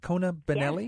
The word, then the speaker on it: Kona Benelli: Kona Benelli? – Yes.